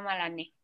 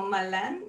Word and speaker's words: Malané: Eh, es joyería y se llama Malané. – Qué bonito, no había escuchado cómo se pronunciaba yo, yo lo decía como Malán, ah, pero bueno que me lo, que me lo haces saber.